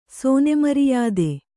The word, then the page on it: ಸೋನೆಮರಿಯಾದೆ: sōne mariyāde